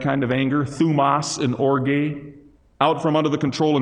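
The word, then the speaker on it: orge (ὀργή): It might be orge, not just thumos, but orge, O-R-G-E, pronounced orge.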